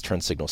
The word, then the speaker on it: TurnSignl: You can download its TurnSignl spelled without the A, so T U R N S I G N L.